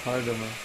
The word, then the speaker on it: Kardama: It was a story of Devahuti and Kardama, pronounced Kardama, Devahuti and Kardama.